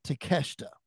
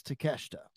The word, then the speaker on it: Takeshita: But they at least gave me the pronunciation of his name, and it's Takeshita. – Takeshita. – So the I is silent. If they were correct, it's Takeshita.